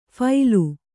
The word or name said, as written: ಫೈಲು